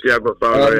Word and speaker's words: Tiago Paulo: So you've had a long creative partnership with producers such as your Tiago Paulo. Not sure if I'm pronouncing Tiago Paulo, correctly. Yes. – Yes. – Tiago Paulo, yes.